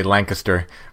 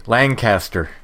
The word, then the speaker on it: Lancaster: We have that here, which, if you're from Pennsylvania, or at least from south central Pennsylvania, you say Lancaster, but everywhere else they say Lancaster.